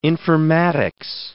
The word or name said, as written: informatics